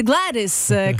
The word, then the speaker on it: Gladys: C'est Gladys, comme les sacs glades, des noms comme les chandelles Glade.